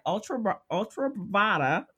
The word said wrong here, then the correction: Truvada: right? – It's PrEP or Truvada.